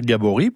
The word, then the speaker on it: Gabory: Oui, et là, on va bien dire Gabory, puisque ça s'écrit G-A-B-O-R-Y.